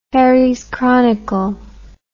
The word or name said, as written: herries chronicle